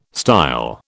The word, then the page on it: style: British English